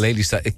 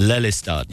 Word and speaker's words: Lelystad: Lelystad. Can you pronounce that? Lelystad? – Lelystad. – Yeah. – Oh, that's very good.